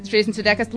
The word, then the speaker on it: Jason Sudeikis: Jason Sudeikis.